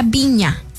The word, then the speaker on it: Vigna: Se pronuncia Viña. Flor Viña, porque se escribe Vigna, pero es Viña, ¿verdad?